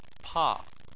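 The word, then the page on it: pa4: tone 4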